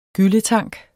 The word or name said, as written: gylletank